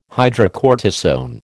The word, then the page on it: hydrocortisone: American English